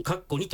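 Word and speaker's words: （2）: カッコ 2 と書いてカッコ2 期と読む、うん、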